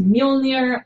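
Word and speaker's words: Mjölnir: What's it actually called? – Mjolnir. – That's it. I always mispronounce that. – It's pronounced Mjolnir, and it's thought to maybe mean lightning.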